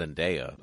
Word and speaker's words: Zendaya: pronounced Zendaya. Apparently, that's how she says it. – It could be. i I've been saying it Zendaya.